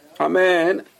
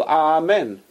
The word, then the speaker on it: amen: Amen, it's a long syllable. Amen. Right? So the first syllable is with a Kamatz. Ah. So Amen. Amen.